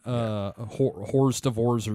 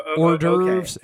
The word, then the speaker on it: hors d'oeuvres: Horst DeVorze, you know, and Tupi. Hors d'oeuvres and toupee.